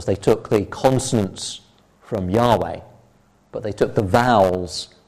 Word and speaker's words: Yahweh: It's thought probably to be Yahweh is the likely pronunciation of these four letters, the way they would vocalize them.